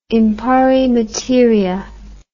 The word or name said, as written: in pari materia